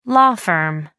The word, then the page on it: law firm: American English